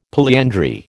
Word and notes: polyandry: American English

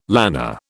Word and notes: lanner: British English

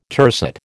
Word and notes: tercet: American English

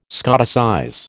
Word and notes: Scotticize: American English

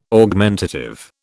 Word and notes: Augmentative: British English

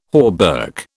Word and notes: hauberk: British English

